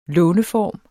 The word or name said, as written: låneform